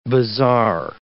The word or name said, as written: bazaar